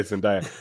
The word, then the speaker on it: Zendaya: it's Zendaya. My daughter keeps correcting me. – Okay, Zendaya.